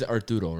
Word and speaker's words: Arturo: Well, my name's Arturo, right? – Mm-hmm. – A-R-T-U-R-O, but I just say that the U-R-O is silent. – You tell everyone to just call you Art? – Yeah, I say the U-R-O is silent.